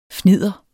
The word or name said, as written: fnidder